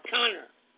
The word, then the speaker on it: Conner: Her name is Chana Corner. – How do you spell that? – C-H-A-N-A. And her last name is spelled C-O-N-N-E-R, Connor. – Oh, Connor.